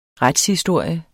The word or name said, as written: retshistorie